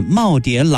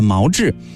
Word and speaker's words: 耄耋: 然而人红是非多， 2019 年陈果参加一档电视节目的时候，因为念错了两个字儿，也就是把耄耋老人的耄耋读成了毛志，便瞬间从一个万人推崇的女神变成了人人唾弃的文盲。